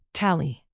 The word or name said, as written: tally